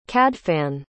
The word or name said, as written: cadfan